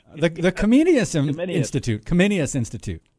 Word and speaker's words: Comenius: And I'm going to say Comenius, but I know I probably mispronounced that. – You know, that was perfect.